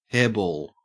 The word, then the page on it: hairball: Australian English